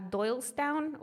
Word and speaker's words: Doylestown: En octubre del 66, la familia se mudó a Doylestown, Ohio.